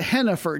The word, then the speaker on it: Jheniffer: Key player to look for offensively for them, tied for second best in league scoring, was the singularly named, I'm going to pronounce it Jennifer, J-H-E-N-I-F-F-E-R.